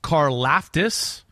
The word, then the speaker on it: Karlaftis: Yeah, number four edge rusher George Karlaftis yeah.